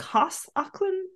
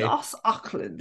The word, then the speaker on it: Joss Ackland: You don't pronounce J's in German. I don't know. – Yeah, Joss. I guess it's a soft J. – Joss Ackland.